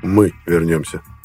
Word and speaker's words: Мы вернемся: «Мы вернемся», — поправил это Хестис, отметив ударение на первом слове.